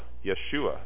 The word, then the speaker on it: Yeshua: Yeshua, really, is the way you would pronounce it in Aramaic.